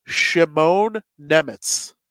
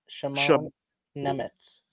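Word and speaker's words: Šimon Nemec: One thing I wanted to talk about quickly, Simone, we got confirmation on how to say his name. It's Shimon Nemitz. So that's how I'm going to be saying it from now on. – Shimon Nemitz? – Yeah. Like Shimon, and then N-E-M-E-T-Z is how you pronounce it.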